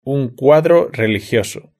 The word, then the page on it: un cuadro religioso: European Spanish